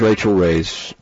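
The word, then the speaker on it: Rachael Ray: He was Rachel Ray's guest.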